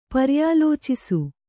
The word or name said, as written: ಪರ್ಯಾಲೋಚಿಸು